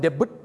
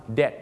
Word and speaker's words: debt: ไม่ได้ออกเสียงว่า debt แล้วก็ไม่ได้ออกเสียงว่า debut ด้วยนะฮะบางคนบอก debut นะฮะเขาออกเสียงว่า debt นั่นเองครับตัว b จะข้ามไปเลยนะครับเขาออกเสียงว่า debt